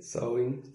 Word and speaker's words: Samhain: por favor decíla bien. Sowin. sowin pero como se escribe, se escribe Samhain, entonces de ahí viene, tras, tomaron para hacer el nombre.